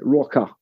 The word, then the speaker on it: Rocka: I also have a psychobilly rockabilly band called Martin Rocker with an A, like a Londoner would pronounce it, Rocker.